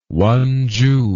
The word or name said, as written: wonju